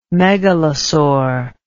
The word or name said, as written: megalosaur